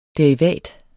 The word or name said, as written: Derivat